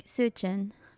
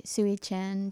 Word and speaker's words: Sui Zhen: And you pronounce it like Sui Zhen, or more like that. And I would say Sui Zhen to make it easier for like the Australian audience.